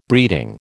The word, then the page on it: breeding: American English